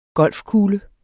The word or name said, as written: golfkugle